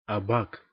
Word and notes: абак: /ɐˈbak/; /a.ˈbak/

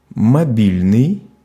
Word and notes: мобильный: /mɐˈbʲilʲnɨj/; mobilnyi

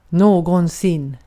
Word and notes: någonsin: svenska

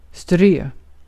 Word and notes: scatter: [ˈskæ.tɜː]; American English

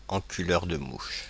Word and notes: enculeur de mouches: [ɑ̃.ky.lœʁ.də.ˈmuʃ]; Paris

